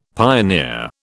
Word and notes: pioneer: British English

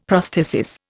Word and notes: prosthesis: Indian

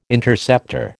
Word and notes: Interceptor: American English

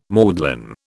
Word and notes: maudlin: British English

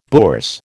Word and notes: bourse: American English

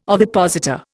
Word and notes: ovipositor: Indian

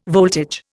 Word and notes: voltage: Indian

